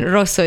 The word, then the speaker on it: Rosso: Rosso, it's quite, you have to roll the R.